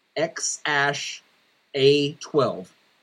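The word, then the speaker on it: X Æ A-12: He basically said the baby's name is pronounced X Ash A twelve.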